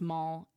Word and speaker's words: MVLL: If you are looking them up online, it is Mall, M-V-L-L, but pronounced Mall, as the kids do.